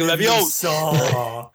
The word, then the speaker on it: Leviosa: Like when they're practicing like Wingardium Leviosa, you have to say it like a certain way, like Emma Watson slash Hermione, like Leviosa.